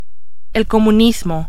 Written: comunismo

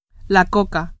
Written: coca

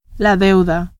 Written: deuda

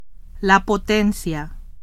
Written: potencia

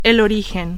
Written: origen

